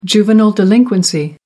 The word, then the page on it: juvenile delinquency: British English